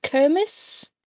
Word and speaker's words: Kermes: K-E-R-M-E-S. – I think it's just Kermes.